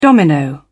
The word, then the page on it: domino: British English